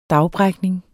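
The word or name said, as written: DAGBRÆKNING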